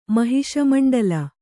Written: ಮಹಿಷಮಂಡಲ

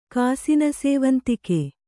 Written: ಕಾಸಿನಸೇವಂತಿಕೆ

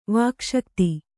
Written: ವಾಕ್ಶಕ್ತಿ

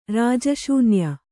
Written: ರಾಜಶೂನ್ಯ

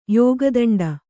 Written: ಯೋಗದಂಡ

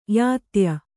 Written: ಯಾತ್ಯ

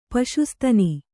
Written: ಪಶುಸ್ತನಿ

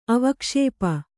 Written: ಅವಕ್ಷೇಪ

